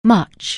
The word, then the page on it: much: anglais